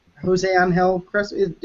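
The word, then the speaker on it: José Ángel Crespo: And that with today's news of Jose Angel Crespo. Am I getting the name right? – Yeah, Jose Angel Crespo.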